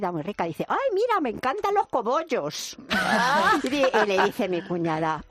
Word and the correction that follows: cogollos: ¡uy, tía, perdona, pero es que no se dice cobollos, se dice cogollos!